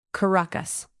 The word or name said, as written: caracas